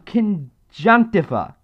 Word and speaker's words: conjunctiva: No, conjunctiva, that's how it's pronounced.